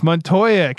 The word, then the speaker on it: Montoya: All my emphases are on the wrong syllables. As Montoya. – Never going to happen. – It's Montoya.